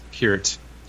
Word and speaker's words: Peart: It's actually pronounced Peart.